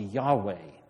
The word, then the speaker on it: Yahweh: The best pronunciation we think is probably Yahweh.